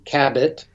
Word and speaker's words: Kabat: My name's spelled with a G, G-E-O-F-F-R-E-Y, and last name is K-A-B-A-T, Cabot, .com.